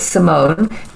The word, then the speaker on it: Simone: You can look me up at Simone, S-I-M-O-N-E. And the last name is Cavalheim. It's K-V-A-L-H-E-I-M for mother, Cavalheim.